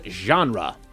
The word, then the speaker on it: genre: The word genre. – Genre. – What do you think, Ted? – French? – Nailed it. Pronounced the French way, this word doesn't get the English treatment.